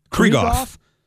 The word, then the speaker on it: Krieghoff: Krieghoff.